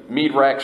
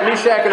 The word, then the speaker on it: Meshach: I used to always say like, I was like, you know, Meadrach, Shadrach. You always get them all mixed up. But Shadrach, Meshach, and Abednego.